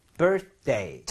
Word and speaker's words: birthday: So, birth하고 윗니, 아랫니 사이에 혀가 살짝 보이면서 birth day인데, 워낙 발음을 빠르게 하다 보면요, th 발음이 거의 나지 않을 수준이 돼서, birthday, birthday. 그래서, th를 억지로, 이렇게 하려고만 하지 말아주세요.